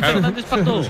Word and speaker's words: Fernández Pardo: En el vídeo de las pronunciaciones, dice Fernández Pardo, ¿eh? – Sí. Dice Fernández Pardo.